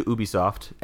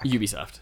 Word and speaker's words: Ubisoft: I'm sure the French pronounce it Ubisoft because they don't know how to say the U, the liquid U sound, but...